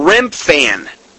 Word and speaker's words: Remphan: Now, Rimian was the Syrian name, pronounced as Rimvan or Remphan, just as Chun was pronounced Chivan.